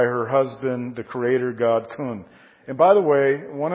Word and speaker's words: Khnum: And Heqet, the frog goddess, was married, and let me just give you Egyptian religion, was married to the creator god called Khum. If you're taking notes, it's spelled K-H-N-U-M. And Egyptian religion said that Khum was the creator god and that he created human bodies on a spinning wheel.